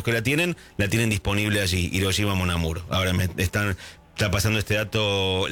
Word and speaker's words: Hiroshima Mon Amour: Hiroshima Mon Amour.